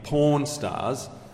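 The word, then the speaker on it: Pawn Stars: And before you go dobbing me into the Archbishop, it's spelled P-A-W-N, Porn Stars.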